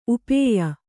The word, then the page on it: ಉಪೇಯ: kannada